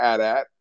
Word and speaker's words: AT-AT: My favorite, obviously, is the AT-AT. That's the only correct way to say it.